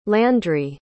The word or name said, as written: landri